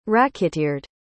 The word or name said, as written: racketeered